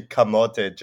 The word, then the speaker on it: camote: So I've had people call me Camel Toe Joe. – Okay. – The username, right? But it's actually Camote Joe. So a camote is it's a Tagalog pronunciation word for a sweet potato.